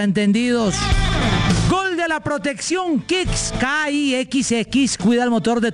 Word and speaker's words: Kixx: Así como los campeones se preparan para ganar, protege tu moto con Kicks, K-I-X-X. Aceites sintéticos y semisintéticos para un máximo rendimiento. Síguenos ya mismo en Instagram como @aceite_kicks. Facilito, @aceite_kicks. Ese kick se escribe muy fácil, K-I-X-X. K-I-X-X, kicks.